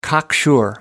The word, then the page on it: cocksure: American English